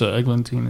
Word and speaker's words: Eglantine: But the rest of them are all buried out in the Old Nauvoo Cemetery, except for Eglantine, which I'm hoping I'm saying her name right. – Yeah, that's right. It's Eglantine. It's Eglantine. A French name.